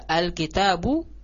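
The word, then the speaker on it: al-kitabu (الكتاب): Alif lamnya dibaca dengan diizharkan, dinampakkan. Ya, contohnya al-kitab. Ya, al-kitabu, kaf, kitab, itu huruf qomariyah. Ketika masuk alif lam, maka cara membacanya dengan dinampakkan alif lamnya.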